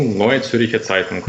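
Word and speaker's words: Neue Zürcher Zeitung: По-перше, як правильно вимовляється назва її? – Ну, «Ноє Цюрхер Цайтунг», або скорочено «НЦЦ».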